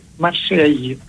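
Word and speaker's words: Marseille: Στα γαλλικά προφέρεται Μασέγι. Ακούγεται το γη, τέλος πάντων. – Ναι, και δεν ακούει, δεν προφέρεται το τελευταίο γράμμα, αλλά...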